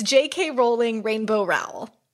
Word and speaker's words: Rowell: And then number 10, which poor Rainbow Rowell rhymes with towel.